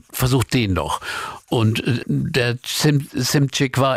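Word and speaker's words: Szymczyk: Joe Walsh sagt Simcik.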